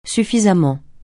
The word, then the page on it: suffisamment: francais